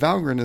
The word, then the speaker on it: Valgrind: And I look at it, it's spelled Valgrind. – Mm-hmm. – And you say it grinned as in smiling. And so I have been mispronouncing that my entire Valgrind using career.